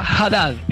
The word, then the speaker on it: Haddad: Y el capitán, como dijimos, que se pronuncia Haddad, en realidad, ¿no?